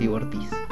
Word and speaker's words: Ivortiz: Yo también lo pronunciaba Ivortis. Eh, pero la gente, escuché mucha gente decir y y Ortiz se pronuncia mi apellido, así que tiene sentido que se pronuncie así.